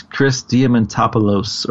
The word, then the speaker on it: Diamantopoulos: It's Chris Diamantopoulos. – Yes, Chris Diamantopoulos, or Loose.